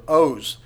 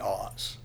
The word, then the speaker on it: Oz: It's actually probably pronounced in the Hebrew Oz, it's the word O-Z, and it's a word for mighty.